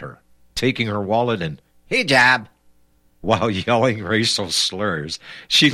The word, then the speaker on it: hijab: Hijab, also hijab.